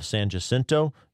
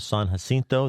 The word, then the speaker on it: San Jacinto: San Jacinto. You non-Texans don't dare call it the Battle of San Jacinto.